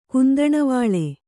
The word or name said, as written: ಕುಂದಣವಾಳೆ